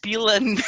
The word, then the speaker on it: pila: Pillar in Portuguese, spelt P-I-L-A, or pronounced pila, means dick.